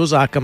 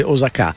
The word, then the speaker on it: Osaka: L'album, lo ripeto, un doppio, è stato registrato al Festival Hall di Osaka, in Giappone, anche qui adesso non si dice più Osaka ma si dice Osaka. Ultimamente negli ultimi anni stanno cambiando tutti gli accenti,